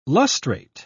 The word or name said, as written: lustrate